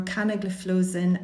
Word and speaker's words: canagliflozin: So just to take care, the only SGLT2 inhibitor licensed in CKD currently are canagliflozin and dapagliflozin. – Great, thanks. Well done for pronouncing those drugs because they're not easy.